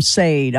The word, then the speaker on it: Sade: Yeah, Sade, man. Yeah, exactly. Yeah, I said it wrong on live on radio. I called him Sade. I'm like, oh, I never lived that one down. So, yeah, Sade.